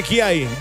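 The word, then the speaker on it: Giay: Giai con la pelota, viene Giai. Giai, más bien, Giai. Agustín Giai es el apellido correcto, la pronunciación correcta de este jugador del Palmeiras.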